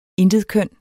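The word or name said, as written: INTETKØN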